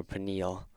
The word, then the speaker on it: Peniel: Or I guess if it, you said it like the way it's pronounced in Hebrew, Peniel.